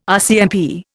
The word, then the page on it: RCMP: Indian